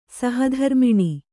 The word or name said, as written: ಸಹಧರ್ಮಿಣಿ